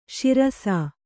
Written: ಶಿರಿಸ್ಸ

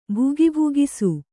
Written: ಭುಗಿಭುಗಿಸು

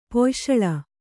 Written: ಪೊಯಶಳ

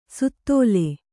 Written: ಸುತ್ತೋಲೆ

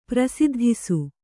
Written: ಪ್ರಸಿದ್ಧಿಸು

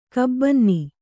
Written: ಕಾಬನ್ನಿ

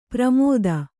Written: ಪ್ರಮೋದ

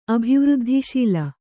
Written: ಅಭಿವೃದ್ಧಿಶೀಲೆ

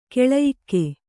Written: ಕೌಳೇಯಕ

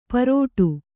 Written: ಪಾರೋಡು

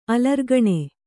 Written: ಅಲರ್ಗಣೆ

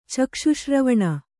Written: ಚಕ್ಷುಶ್ರವಣ

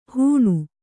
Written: ಹೂಣು

